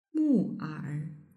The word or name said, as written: muer